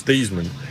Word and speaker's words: Theismann: The way you pronounce his name is Theismann, but but they wanted to do a Heisman campaign for him at Notre Dame, and he became Theismann. And then that's just, at that point, you just quit correcting people.